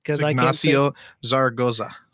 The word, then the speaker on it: Ignacio Zaragoza: Ignacio Zaragoza.